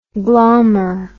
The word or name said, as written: glaumer